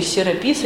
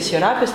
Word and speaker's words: Серапис: Учитель Серапис или Серапис, там по-разному ставится ударение.